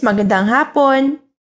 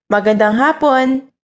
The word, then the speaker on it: magandang hapon: In Filipino, good afternoon is magandang hapon, magandang hapon. Let's break it down by syllable, magandang hapon. Now let's hear it once again.